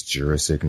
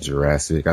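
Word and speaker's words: Jurasik: And his name is Jurassic, not Jurassic. I think it was watching one of the commentaries where I first learned the correct way to say Peter Jurassic.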